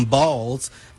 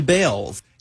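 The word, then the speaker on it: Baals: After all, the family did change the pronunciation of their last name from Balls to Bales.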